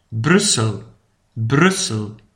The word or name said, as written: Brussel